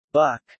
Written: buck

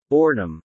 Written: boredom

